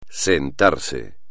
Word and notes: sentarse: espagnol

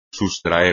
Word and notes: sustraer: espagnol

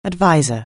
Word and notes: adviser: anglais

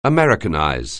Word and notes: americanize: anglais

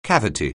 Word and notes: cavity: anglais

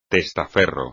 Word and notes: testaferro: espagnol

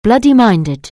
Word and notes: bloody-minded: anglais

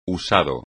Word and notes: usado: espagnol

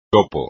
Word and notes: topo: espagnol

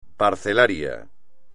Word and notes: parcelario: espagnol

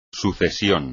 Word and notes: sucesión: espagnol

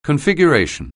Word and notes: configuration: anglais

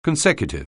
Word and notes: consecutive: anglais

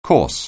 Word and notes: course: anglais

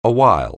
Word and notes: awhile: anglais

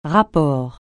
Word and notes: rapport: francais

